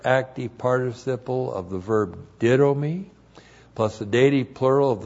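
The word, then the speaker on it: didomi (δίδωμι): Didomi. D-I-D, long O-M-I, didomi.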